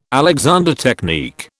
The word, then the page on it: Alexander technique: British English